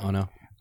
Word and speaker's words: Ono: O-N-O, however you pronounce that. – That's Ono.